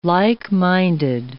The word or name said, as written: like-minded